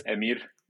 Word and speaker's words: Amir: So also, it's not often that I get introduced as Amir, which is the right way to pronounce it. So right. Not usually, you know, being in the States, when I introduce myself, it's Amir. But yeah, it's Amir. Amir Dukic. But for those that can't quite pronounce the R, I'll go with Amir.